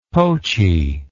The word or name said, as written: poachy